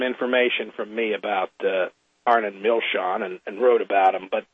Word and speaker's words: Milchan: That first name is spelled A-R-N-O-N. The last name is spelled M-I-L-C-H-A-N. It looks like Milken or Milchen, but it's pronounced Milshon, Arnon Milshon.